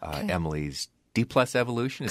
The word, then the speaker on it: Emily's D+ Evolution: So I want to start out by talking about your new album, Emily's D+ Evolution.